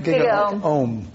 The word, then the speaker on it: GigaOM: And GigaOhm, am I saying that right? – GigaOhm. – I practice yoga. I should know that. That's GigaOhm. And Chelsea Sexton, an EV advocate and consultant.